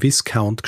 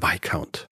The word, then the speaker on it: Viscount: Und schließlich habe ich auch noch von einem Viscount gesprochen, ist natürlich der Viscount. Mhm.